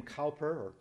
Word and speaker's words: Cowper: He and William Cowper, as it's often pronounced,